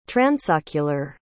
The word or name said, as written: transocular